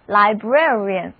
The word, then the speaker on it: librarian: 我们把 library, 图书馆这个单词的结尾字母 y 去掉，然后呢加上名词后缀 r-a-n，于是图书馆馆长 librarian 就在我们的面前了。注意这个单词的发音，第一个字母 A 的发音是 air，而后面的字母组合 IA 读作 ear，整个单词重音第二个音节。